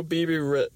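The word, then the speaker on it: Bebe: Baby or BB. – Oh, BB Rexha.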